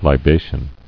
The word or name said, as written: Libation